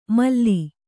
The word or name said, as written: ಮಲ